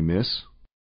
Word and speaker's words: miss: Excuse me, miss. Excuse me, miss. – دقت داشته باشید که حرف آی را مانند ای تلفظ نکنید. یه حرفی مانند ای و ای. بین این دو.